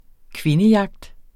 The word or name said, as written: kvindejagt